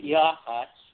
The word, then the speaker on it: Yachats: By the way, you did pronounce it correctly. At least thank you. – We pronounce it Yawhats.